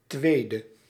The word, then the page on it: tweede: /ˈtʋeːdə/